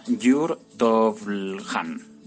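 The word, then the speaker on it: Jure Tovrljan: Vale, y ya por último voy a hablaros de logotipos que propone para la cuarentena del, coronavirus el artista Jur Tovlhan.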